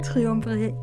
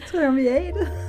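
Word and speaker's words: triumviratet: Tilsammen blev de kaldt triumfvariatet. Er det rigtigt udtalt? – Triumviatet. – Triumviatet.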